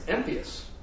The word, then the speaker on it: impious: And only because of Google do I know how to actually pronounce that word. It's impious.